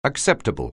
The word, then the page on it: acceptable: anglais